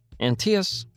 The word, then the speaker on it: Antaeus: Rhymes with Antias. That is literally how we tell people to pronounce our name. It sticks. Antias, come see us.